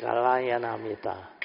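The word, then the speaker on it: Kalyāṇamitta: And to all my friends here, I cannot pronounce the word Kalia. – It's Kalayanamita. – Kalayanamita.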